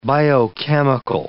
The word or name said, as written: biochemical